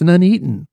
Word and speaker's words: Nuneaton: And then he grew up in Nuneaton. I mean, there's none for the first syllable, but also it's Nuneaton, Warwickshire.